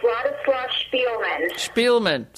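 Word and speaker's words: Władysław Szpilman: And but there's something else I need to talk to you about, and I don't know if I'm pronouncing this right, but yes. – Vladislav Zippelman? – Vladislav Spielman. – See, I'm wrong.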